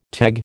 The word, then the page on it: teg: American English